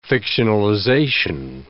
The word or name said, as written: fictionalization